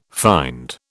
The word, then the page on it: find: British English